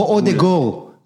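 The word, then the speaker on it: Ødegaard: כמו אודגור. אודגור כותבים עם A, הרי, אבל אודגרד נכתב. אבל אומרים אודגור, אז יש את הקטע הזה בשפות, אתה יודע, פה ב...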